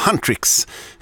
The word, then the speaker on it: Huntrix: Sen fick jag lära mig att det ska ju vara Huntrix. Det är så det uttalas.